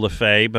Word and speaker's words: Lafever: Not only do I remember Joe LaFay, but I remember that he spelled his name LaFever, but pronounced it LaFay, which I can't tell you why.